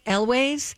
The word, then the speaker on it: Elwes: Okay, so how do I say his name? Cary Elwes.